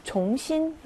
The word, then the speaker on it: 重新: 얘는 무거울 중자잖아요. 그래서 쫑이라고 읽어야 맞는데 여기선 쫑으로 읽지 않고 종으로 읽습니다. 네, 다시라는 의미로 활용이 될 때는 종하고 이성으로 읽어주셔야 돼요. 종신 요 단어 따로 좀 기억하시고요.